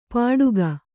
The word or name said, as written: ಪಟುಕ